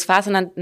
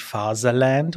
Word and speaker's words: Faserland: Es ist der beste Buchtitel, den es gibt, denn wenn Deutsche äh, das englische Wort Fatherland ähm, äh, deutsch aussprechen, nämlich Faserland oder Faserland, äh, dann ergibt es eben dieses Faserland.